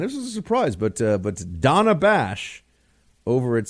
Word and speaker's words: Dana: It's spelled Dana, but she likes to be called Donna.